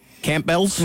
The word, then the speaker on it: Campbell: Campbell's? – Mm-hmm. – Okay. – Or Campbell's. I didn't pronounce the P.